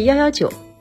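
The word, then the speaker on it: 119: 为什么火警电话是幺幺九？一也可以读作幺，它与药同音，九是救的意思，所以幺幺九也就是要要救。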